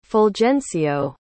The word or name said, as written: fulgencio